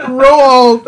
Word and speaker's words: Roald: It's not Ronald. There's no N in it. – Oh my goodness! I just saw that for the first time. Roald.